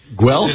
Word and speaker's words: Guelph: and I don't know how to pronounce this, Leo, G-U-E-L-P-H? – Guelph, right? – Guelph? – It's in Ontario. Guelph. Yeah. We have an actual Ontarian in studio, and he's saying it's Guelph.